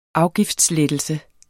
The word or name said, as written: afgiftslettelse